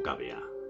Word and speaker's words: cavea: Bueno, se pronuncia cavea o cavea, indistintamente.